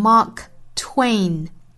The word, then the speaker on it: Mark Twain: 最后我们来讲一下马克·吐温这个名字的读法，读成 Mark Twain, Mark Twain. 后面这个部分有一些不好读哈，应该是一个 t w 辅音连缀发 t w t w 再接后面的双元音 a 和这个鼻音的组合，嗯，ain ain twain twain。